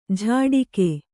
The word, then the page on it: ಝಾಡಿಕೆ: Kannada